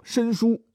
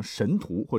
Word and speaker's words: 神荼: 树上有二神掌管，一个叫做申叔，一个叫做玉律啊。这两位大神的这个读音要专门去查，否则的话，您可能会念成神荼或者玉垒哈。